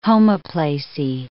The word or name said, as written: homoplasy